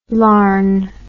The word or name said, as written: larn